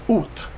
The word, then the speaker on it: août: Août.